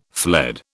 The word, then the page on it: fled: British English